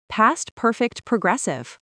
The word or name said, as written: past perfect progressive